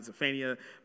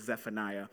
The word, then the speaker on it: Zephaniah: And then as I grew in my understanding and my discipleship and learned how to pronounce it, I realized it was not Zephaniah, but it was Zephaniah.